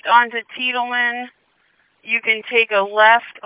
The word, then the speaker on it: Tiedemann: But it's Tiedemann. Looks like it's spelled T-I-E, and then Demon.